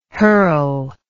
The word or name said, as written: herl